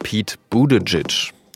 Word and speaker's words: Pete Buttigieg: Und in der letzten Folge haben wir zum Beispiel einen der Präsidentschaftskandidaten der Demokraten falsch ausgesprochen. Er heißt natürlich Pete Buttigieg.